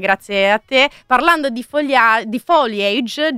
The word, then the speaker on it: foliage: che in realtà la pronuncia corretta di foliage è all'inglese foliage, non foliage, quindi in francese.